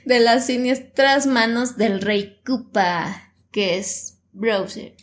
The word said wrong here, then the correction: Bowser: Es, es Bowser.